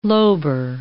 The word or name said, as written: lobar